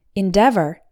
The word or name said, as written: endeavor